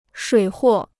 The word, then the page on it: 水货: shuǐ huò